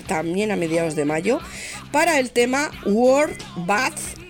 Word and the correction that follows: War Bath: que espero pronunciar bien, por favor. War Bath sería, War Bath, madre mía, mi inglés, qué maravilla.